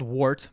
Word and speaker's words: wort: And so it's spelled wort, but it's pronounced wort, W-E-R-T. – Yeah. – It's spelled W-O-R-T.